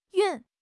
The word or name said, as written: 熨